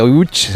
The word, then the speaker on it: Łódź: esta ciudad, aunque se pronuncia Łódź, se escribe Lodz, por si alguien lo busca Lodz, en Google. Casi no tiene nada que ver la pronunciación.